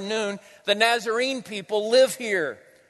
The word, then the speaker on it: Nun: The Arabic letter is called Nun. That's how you pronounce it, Nun.